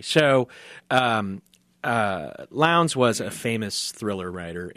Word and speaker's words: Lowndes: It was written in 1914 by Marie-Belloc Lowndes, which rhymes with Towns.